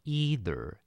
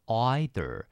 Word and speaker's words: either: 好，那第二个字呢叫做 either，不过呢有些美国人可能会念成 either，所以你不要说你怎么念错了，是它有两种发音，大部分都念成 either，不过有些人会把它念成 either。